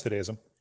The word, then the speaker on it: fideism: Am I pronouncing that right? I don't remember what he called it. – Yeah, I think it's fideism.